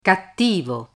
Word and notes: cattivo: Italian

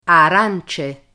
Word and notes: arance: Italian

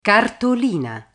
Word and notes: cartolina: Italian

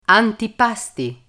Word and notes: antipasti: Italian

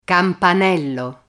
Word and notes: campanello: Italian

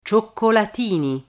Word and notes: cioccolatini: Italian

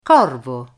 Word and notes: corvo: Italian

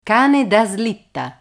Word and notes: cane da slitta: Italian

